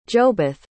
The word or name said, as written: jobeth